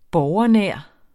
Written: borgernær

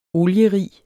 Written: olierig

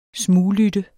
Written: smuglytte

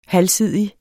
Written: halvsidig

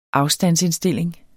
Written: afstandsindstilling

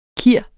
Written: kir

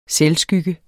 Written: selvskygge

